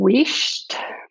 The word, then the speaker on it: Wheesht: It's called Wished. So it's spelled Wheesht, and it's a Scottish or Northern England word for hush or for being quiet.